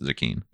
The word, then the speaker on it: Zecchin: Z-E-C-C-H-I-N. It's pronounced Zucchine. – That's weird. It sounds like zucchini. – Yeah. – So, what? Tell me about that. – Well, everyone always mispronounced my last name, but Zucchine is from Venice. It's a Venetian last name, which is why it doesn't have a vowel at the end, like so many other Italian last names with a vowel.